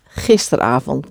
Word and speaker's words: gisteravond: A-V-O-N-D. Avond. Avond. Put them together and you get gisterenavond. But usually it is spelled and it's said gisteravond without the E-N of gisteren. Okay?